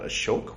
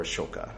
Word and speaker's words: Ashoka: Yes, that is from a movie called Ashok, which you can see here, or Ashoka. It's they don't pronounce the A usually, unless they're talking to foreigners,